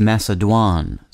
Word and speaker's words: macédoine: The word of the day for November 24th is Macédoine, spelled M-A-C-E-D-O-I-N-E. The first E in Macédoine is spelled with an acute accent rising to the right.